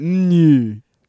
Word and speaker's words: Neuer: Mon- Mon- no, he's he wanted us to say it like real fancy, right? Like Manuel Neuer.